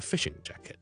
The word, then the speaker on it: fishing jacket: Okay, four syllables, 四个音字 and then 重音是个 fish 度 so a fishing jacket.